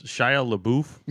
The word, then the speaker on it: Shia LaBeouf: But in other movie news, Shia LaBeouf, is that how you pronounce it? – Yep.